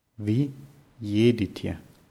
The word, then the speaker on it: едете: Вы едете.